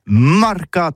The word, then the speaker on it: marcat: Eh bien, dis ça à tous, mais le mercat de l'Uni, le mercat des rebelles, mais en gascogne toulousain, c'est prononcé les marcat, avec un A, marcat.